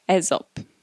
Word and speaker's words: Aesop: Did you know that this expression derives from a fable by Aesop? Is that how you pronounce it? Pretty sure, yes, you know, not the soap company that's based in London, but the Greek fabulist storyteller dude.